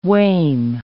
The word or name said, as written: wame